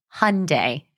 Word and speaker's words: Hyundai: How do you say that car? Hyundai? – Hyundai. I think it rhymes with Sunday.